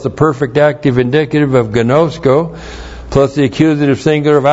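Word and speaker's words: Gnosko (γινώσκω): Gnosko. G-I-N long O-S-K long O. Gnosko.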